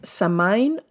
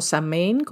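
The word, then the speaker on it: Samhain: La primera es Samain o Samain, como ustedes quieran pronunciarlo, y es el año nuevo en las tradiciones de la diosa y de la magia y corresponde a la Noche de Muertos.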